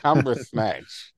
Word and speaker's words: Cumberbatch: The Ancient One is finally convinced to relinquish the Time Stone when told that her successor, Doctor Strange, played by Flumberbatch Cunderbung, Benedict Cumberbatch. Get it right.